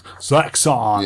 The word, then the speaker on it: Zaxxon: I think you'll find it's pronounced Zaxxon.